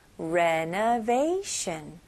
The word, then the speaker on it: renovation: 다음은 renovation입니다. 네, 발음은 앞부분만 다르고 마지막 부분은 비슷해요. renovate 그 다음에 sh 발음으로 shun. renovation. renovation. 여기는 강세가 어디에 들어가죠? 네, vay 부분에 좀 강세 주시면 좋아요. renovation.